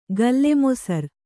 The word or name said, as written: ಗಲ್ಲೆಮೊಸರ್